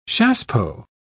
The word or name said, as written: chassepot